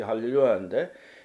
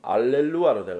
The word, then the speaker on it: 할렐루야: 그 할렐루야가 히브리어 원래 히브리어죠. 그게 할렐루야인데 헬라식 발음은 알렐루아로 되어 있습니다.